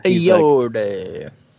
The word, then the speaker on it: Ayoade: We got Vince Vaughn, Jonah Hill, Richard Ayoade. I think that's how you pronounce his name. Yeah. – Ayoade.